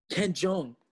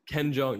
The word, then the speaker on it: Jeong: How do you say his name? – Ken Jeong. – Ken Jeong, yeah, he's in that.